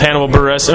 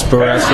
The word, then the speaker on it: Hannibal Buress: All right, I'm at Chicago Joe's, and I'm with Hannibal Buress. Am I pronouncing your name correctly? – Yeah, it's Buress.